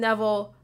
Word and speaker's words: Neville: the Jason Neville Funky Soul Band. – That's right. You said it right.